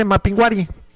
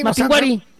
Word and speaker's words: Mapinguari: Ahí dice Mapinguari. – Dice Mapinguari. Ah, es que no tiene acento en la I. – No tiene acento en la I. – Mapinguari.